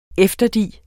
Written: efterdi

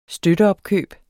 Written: støtteopkøb

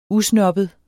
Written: usnobbet

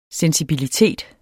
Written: sensibilitet

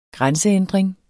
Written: grænseændring

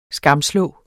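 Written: SKAMSLÅR

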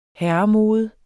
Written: herremode

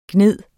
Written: gnide

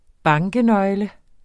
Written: bankenøgle

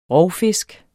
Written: ROVFISKEN

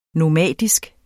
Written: nomadisk

